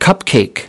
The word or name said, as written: cupcake